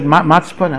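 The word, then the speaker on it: matzpun (מצפון): Mitzpun or matzpun, it's mem tzadik pei vavnun. They pronounce it matzpun, I'm not sure. Okay, matzpun, okay. Chochmas hamitzpun, okay.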